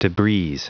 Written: debris

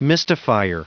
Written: mystifier

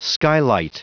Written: skylight

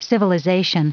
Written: civilization

